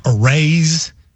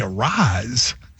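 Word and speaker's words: Arraez: And if you see A-R-R-A-E-Z, I would have thought Arrays, Ares. And when he told me Arise, I was like, you're screwing me.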